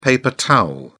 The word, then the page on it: paper towel: British English